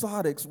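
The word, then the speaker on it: sarx (σάρξ): Now, here the word flesh in the Greek is pronounced sarx, which implies humanity.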